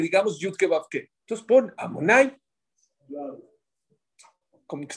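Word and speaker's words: Adonai: ¿Cómo se pronuncia? Amonai. Se escribe de una manera y se lee de otra manera.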